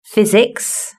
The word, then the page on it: physics: British English